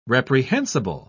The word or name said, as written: reprehensible